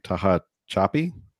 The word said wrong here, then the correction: Tehachapi: California, Tehachapi, California stayed open and mass free as well.